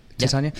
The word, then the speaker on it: Titania: Did I pronounce it right, Titania? – Yeah.